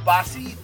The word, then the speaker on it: Bosse: All right, so today we have Sarah Bosse, the director of the Madera County Public Health Department. Did I get everything right? – Uh, it's pronounced boss.